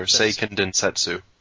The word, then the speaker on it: Seiken Densetsu: Oh, it's Densetsu. I put an extra T in there. Seiken Densetsu. – Seiken Densetsu. So, pronunciation cast over.